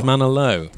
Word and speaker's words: Osmanoglu: Osmanoglu. – Rhymes with Barry Manilow.